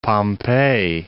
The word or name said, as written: Pompeii